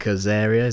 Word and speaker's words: Azaria: I saw the other day Hank Azaria, is that how you say his name? – Yep.